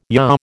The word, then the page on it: yaw: American English